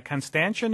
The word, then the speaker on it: Constantia: David in Constantia, New York. Is that right? – There you go. Not too many people get that pronunciation right there, Ira.